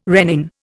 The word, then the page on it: rennin: Indian